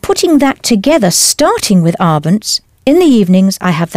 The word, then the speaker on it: abends: In the evening, or evenings, is abends. Do pronounce the S clearly.